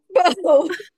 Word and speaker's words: Bow: Is it Bow? – Bow. – And the bow breaks.